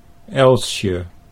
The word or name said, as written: Elsje